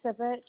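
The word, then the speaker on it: Sipich: Riley Sivich.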